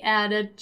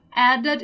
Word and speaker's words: added: Here, once again, let's be careful with the pronunciation of the verb in the simple past. Added, okay? So added is one of those verbs in which the ED sound is stressed, is strong.